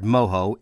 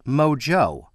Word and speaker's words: mojo: Many North Americans would look at the word mojo in its printed form and pronounce it mojo. In the Spanish, from where it comes, it is of course pronounced mojo, because the letter J is pronounced as an H, as in jalapeno.